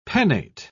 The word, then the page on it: pennate: British English